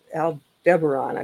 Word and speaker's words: Aldebaran: The ones that are from Aldebaran, I believe is the correct pronunciation.